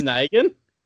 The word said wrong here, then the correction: Nguyen: Wynn! Pronounced Wynn! – James Wynn. – James Wynn? – He's Vietnamese. – I know a guy who has that same last name. He pronounces it as Wynn.